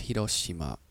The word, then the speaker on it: Hiroshima: Well, the R isn't an R. It's Hiroshima. Hiroshima. Okay. So Hiroshima. No one's going to pronounce it like that. Hiroshima is how people here would say it.